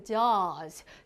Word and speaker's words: jaws: 어, 실질적인 발음은 어떻게 즈 사운드죠. 그래서 jaws, j a 뭐라고 j a w 네, 이렇게 말하는데요.